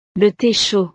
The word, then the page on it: le the chaud: frances